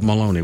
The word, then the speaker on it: Molony: Think of baloney, but Maloney with an M, right? Janelle Maloney. – No, it's spelled like colony. That's a better way. – That's a better way. You're right. Colony is a much better way.